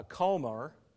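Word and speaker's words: comer (חֹמֶר): It just so happens, Homer, if you pronounce it as it is in Hebrew, it's a comer, and it means a heap or a pile, and it's equivalent to about 8 bushels or 98 gallons worth.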